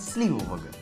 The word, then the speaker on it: сливового: Нашла классный рецепт сливового пирога. Правильно говорить, сливового.